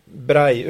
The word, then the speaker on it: Braille: Braille uttalas det på franska. Braille. – Fast två L är på spanska, blir det J.